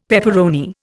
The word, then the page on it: pepperoni: Indian